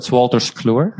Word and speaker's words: Wolters Kluwer: Your first major role was as an IT consultant in, is it Wolters Fluma? Is that a bad pronunciation? – Sure. No, so it's Wolters Kluwer.